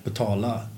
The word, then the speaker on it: betala: I was pronouncing it Swedish way, Betala.